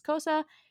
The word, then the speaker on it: Xhosa: But essentially, some of the words are going to be in Xhosa, which is a language name that I can't even properly pronounce because there should be a click in there, and I can't do that. But the American English pronunciation is Xhosa, and that is one of the clicking languages that you hear about from Africa, and obviously I don't have that capacity.